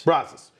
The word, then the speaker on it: Brazos: On the banks of the Brazos? You got corrected, and I want to make sure I say it right. – Brazos.